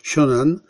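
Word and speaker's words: Xiong'an: Rozpoczęto budowę linii kolejowej Pekin-Siong'an. Tak się to wymawia. I zacytuję te cztery zdania. Kolej międzymiastowa łącząca Pekin z Siong'an, New Area rozpoczęto budowę tej kolejki 28 lutego.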